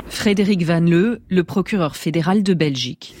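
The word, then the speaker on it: Van Leeuw: On va écouter le procureur fédéral de Belgique, Frédéric Van Leuve, je sais pas si ça se prononce comme ça. – Van Leuve. – Van Leuve, voilà.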